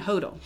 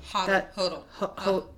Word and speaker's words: Hodel: How do you say his last name again? Hodel. – Hodel. Like he was a junior.